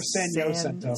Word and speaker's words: San Jacinto: Is it pronounced San Jacinto or San Jacinto? – San Jacinto. – Okay. – You get punched in the head if you call it San Jacinto. – It's San Jacinto.